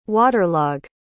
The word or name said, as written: waterlog